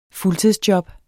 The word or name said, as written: fuldtidsjob